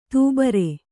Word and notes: ದುಬಾರಿ: dubāri

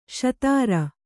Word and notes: ಶೂದ್ರ: kannada

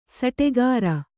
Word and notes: ಸುಟಿಕರ: Kannada

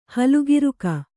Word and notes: ಹಲುಗಿರುಕ: halugiruk

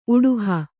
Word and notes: ಉಡುಹು: Kannada

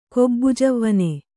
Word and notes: ಗಬ್ಬುಜವ್ವನೆ: gabbu javvane

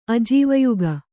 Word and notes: ಅಜೀವಯುಗ: kannada

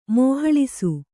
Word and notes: ಮೋಹಳಿಸು: Kannada

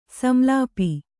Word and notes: ಸಂಲಾಪ: samlāpa

